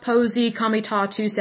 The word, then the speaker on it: Posse Comitatus: Um, it's probably one of those things every once in a while someone might pronounce it as Posse Comitatus Act but I believe it's pronounced Posey Komitatu's Act.